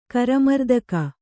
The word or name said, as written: ಕರಮರ್ದಕ